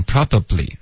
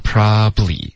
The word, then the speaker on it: probably: OK, probably, 不要发成 probably, OK, 用它的这个缩写的发音 probably, OK, 两个音节就够了。